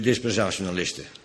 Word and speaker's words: dispensationalisten: Ik heb er gekend, die zeiden, nou dan ben ik altijd belazerd geworden door die dispensationalisten. Dispensationalisten, zeg ik het goed? Ja.